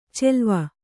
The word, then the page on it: ಚೆಲ್ವ: celva